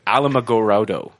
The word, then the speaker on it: Alamogordo: Alamogordo, New Mexico.